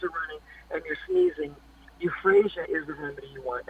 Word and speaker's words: euphrasia: And one of the first remedies that's helpful for allergies is a remedy called euphrasia. And it's spelled E-U-P-H-R-A-S as in Sam, I-A, euphrasia.